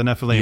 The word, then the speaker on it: Nephilim: Yeah, the Nephilim.